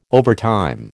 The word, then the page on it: overtime: American English